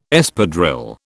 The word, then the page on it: Espadrille: British English